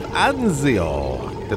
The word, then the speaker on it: Anzio: But we're starting off with the Ballad of Anzio. Did I pronounce that right, Daffodil? – I would go with that.